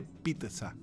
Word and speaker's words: pizza: Pero en lo que pones la masa con el queso arriba no se dice pizza con X, se dice pizza con T. Esa es la correcta pronunciación de la doble Z en italiano, es pizza, pizza.